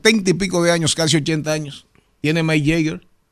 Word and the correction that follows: Mick Jagger: O Jagger, ¿cómo se pronuncia? Mick Jagger. – ¿Eh? – Mick Jagger.